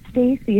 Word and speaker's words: Stacy: So it's Stacey. It's just S-T-A-C-Y. And then it's Peterson with a D as in David. Weird spelling, P-E-D-E-R-S-O-N.